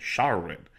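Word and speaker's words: Shawaryn: Mike Schawarin. Yeah, I'm sorry. It's Shaw, A-R-Y-N. Schawarin.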